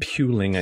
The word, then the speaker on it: puling: Pulling? Pulling. – Puling, I think. Puling? Was going to give you three points, but I'll only give you two because you mispronounced it.